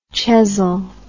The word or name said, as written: Chesil